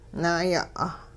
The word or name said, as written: naay'a'